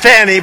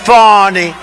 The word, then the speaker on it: Fani: F-A-N-I. That's pronounced Fanny, but you're not allowed to say it. It's Fawny.